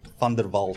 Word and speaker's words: van der Walt: Van der Walt.